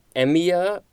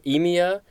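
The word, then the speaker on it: aemia: Next, we have the suffix uh, emia or emia.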